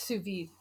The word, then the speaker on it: sous vide: It's sous vide.